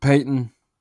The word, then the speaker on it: Paton: Of course, um, five things about um, Paton, I guess is how you're going to say his name. Pronounce it Paton.